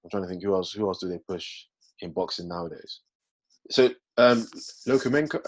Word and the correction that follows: Lomachenko: I don't know how to pronounce his name. Lokomenko? – Lomachenko.